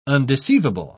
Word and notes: undeceivable: British English

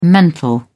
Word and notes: mental: British English

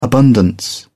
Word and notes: abundance: British English

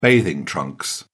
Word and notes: bathing trunks: British English

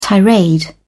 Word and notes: tirade: British English

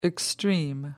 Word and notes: extreme: American English

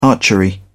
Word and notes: archery: British English

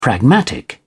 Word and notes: pragmatic: British English

